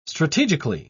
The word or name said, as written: strategically